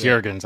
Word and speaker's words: Jurgens: And we're also in the process of some, like you mentioned, is it Jurgens or Jurgens? – Yeah, I guess it's Jurgens. I guess I was saying it wrong.